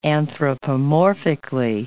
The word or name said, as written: anthropomorphically